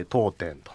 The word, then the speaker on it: 読点: 読むに点と書いて当店と。